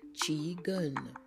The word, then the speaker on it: qigong: The first word, F-A-Q-I-R, is pronounced fakir. Fakir, it is from Arabic. Then the second word, Q-I-G-O-N-G, is a word that we have borrowed from the Chinese language, and it is pronounced qigun, qigun. The Q is pronounced like a C-H.